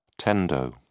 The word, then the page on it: tendo: British English